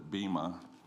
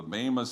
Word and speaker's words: Bema: For decades I said Bema, and that was a mispronunciation. The Bema seat of Christ.